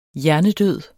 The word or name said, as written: hjernedød